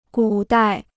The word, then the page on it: 古代: gǔ dài